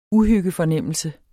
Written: uhyggefornemmelse

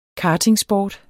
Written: kartingsport

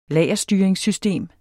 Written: lagerstyringssystem

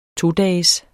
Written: todages

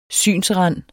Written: SYNSRANDENE

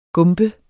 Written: gump

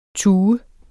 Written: tue

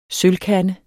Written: sølvkande

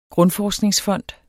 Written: grundforskningsfond

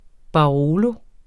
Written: Barolo

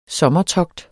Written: sommertogt

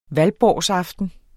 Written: valborgsaften

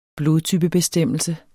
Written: blodtypebestemmelse

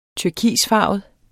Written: turkisfarvet

